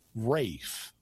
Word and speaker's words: Ralph: because the name Ralph, R-A-L-P-H, I know this one. – Is sometimes pronounced Rafe. – Yeah. – That's like the L drops out.